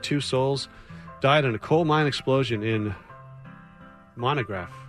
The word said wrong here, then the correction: Monongah: So Monongah.